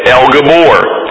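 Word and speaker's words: El Gabor (אל גבור): You see, the original Hebrew of this title is pronounced El Gabor, and that happens to be found in three other places in the Old Testament.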